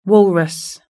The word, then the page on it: walrus: British English